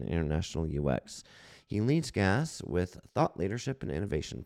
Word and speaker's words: GAAS: We pronounce it GAS, G-A-A-S, Globalization as a Service.